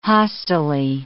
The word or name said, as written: hostilely